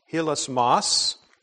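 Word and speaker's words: hilasmos (ἱλασμός): The Greek word here that is used is pronounced hilasmos.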